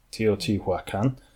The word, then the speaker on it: Teotihuacan: So there's this this city-state, so it was called Teotihuacan. Teotihuacan. – Mm-hmm. – Well, yeah. I'm probably butchering that. – No, you probably nailed it first time.